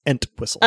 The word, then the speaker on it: Entwistle: I got to pronounce the T, Entwistle.